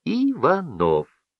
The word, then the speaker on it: Иванов: Ударение у Николая Константиновича падало на последний слог. Фамилия его читалась Иванов.